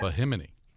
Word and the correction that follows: Bimini: right? Am I saying it right? – Bimini in the Bahamas. – No, I guess I wasn't.